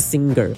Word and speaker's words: singer: 那那个 g 的声音不能那么大，因为不是 singer，因为 ng 就是嗯，对吗？嗯，那个注音，我们这个 ng 这个发音呢，跟注音符号的嗯是一样的，所以嗯加耳叫 nger，nger，所以歌手叫 singer，singer，singer，应该没办法完全没有 g 的声音，但是就是不能是 singer，是 singer，singer。